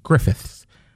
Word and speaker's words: Griffiths: I said it right, Griffiths.